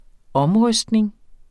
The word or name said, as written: omrystning